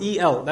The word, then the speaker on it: El: But the people of Canaan, the Canaanites, they were actually worshippers of another god named El, E-L. That's how it's pronounced, El.